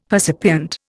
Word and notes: percipient: Indian

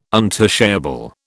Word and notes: untouchable: British English

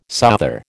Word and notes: souther: American English

